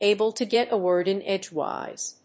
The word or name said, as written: able to get a word in edgewise